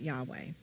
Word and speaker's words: Yahweh: At some point, there's a whole backstory to the name Yahweh, but originally, I believe the vowels were removed, and so it's just the YHWH, but we now pronounce it Yahweh.